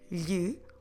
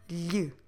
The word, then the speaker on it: lhe: L H E lhe. I know this one, it's not a very easy pronunciation. We don't have any similar sound in English that we can use to compare. So I'll try to repeat and I will repeat and you'll try to follow.